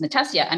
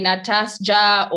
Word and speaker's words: Natasha: And there was one lady at my work who's Polish, but she was like, by the way, in my country, your name would be pronounced Natasha. And I was like, oh my word, that's actually the correct pronunciation. So maybe a handful, very few. Most people say Natasja or all sorts of odd things, but rarely ever Natasha.